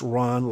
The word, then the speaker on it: Rohn: Business philosopher Jim Rohn, R-O-H-N, and it's pronounced Ron like Khan, K-O-H-N.